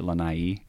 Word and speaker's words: Lanai: Is it Lanai? – Yeah. – Okay, I gotta say that right.